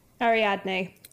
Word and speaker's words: Ariadne: Learns one of them is an enslaved dragon named Adarin? – Ariadne. – What? – Ariadne. I did actually look up how to pronounce this because I could not bloody read it. Ariadne.